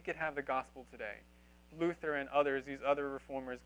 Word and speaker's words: Luther: All right, so let's start at the beginning with Luther. Pronounce Luther, because there's no TH sound in German. You wouldn't say th.